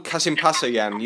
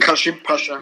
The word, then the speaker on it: Kasımpaşa: Kasımpaşa'yan. You know how to pronounce it better than I do. – Kasımpaşa.